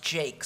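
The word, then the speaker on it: Jaques: The speech is made by somebody whose name is spelled Jacques, but an Englishman assured me it's always pronounced Jake, so we'll call him Jake.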